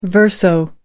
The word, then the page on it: verso: American English